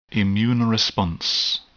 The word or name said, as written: immune response